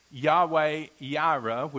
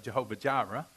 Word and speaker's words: Yahweh Yireh: And Abraham named the place Yahweh Yireh, which we tend to pronounce as Jehovah Jireh. But that's actually the original language, which means the Lord will provide.